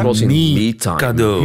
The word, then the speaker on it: Mikado: Uh, Willem, Willem uh, Doeven, Ronnie Tietgat en Rudy Helven kwamen met Mikado. Ja. Schitterend. – Op zijn, Mikado. Op zijn Engels uitgesproken. Met een E. Ja. – met, een, E. e. – Ja, ja. Mikado.